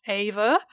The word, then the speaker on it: Eva: one of her biographies is The Lost Life of Ava Braun. Sorry, I'm going to keep going back to, like, Eva, even though that's anglicized. Technically, it's pronounced Ava. I'll try my best to do the Germanic Ava pronunciation, but I'm sorry, I am very English.